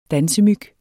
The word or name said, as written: dansemyg